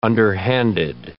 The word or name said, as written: underhanded